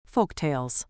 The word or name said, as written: folktales